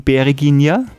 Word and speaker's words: Bereginja: Habe ich das richtig ausgesprochen, Bereginja? – Ganz richtig, genau.